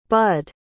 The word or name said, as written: budd